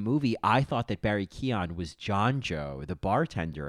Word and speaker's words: Keoghan: And then I saw that was, and I looked this up and I was like, oh, look at his last name, Barry Keoghan.